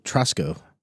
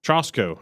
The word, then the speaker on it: Trosko: T-R-O-S-K-O. – We're going to have to ask him. – We are. And supposedly he's like famous in... – Trosko. – Trosko. I believe that is how you say it.